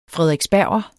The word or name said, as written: frederiksberger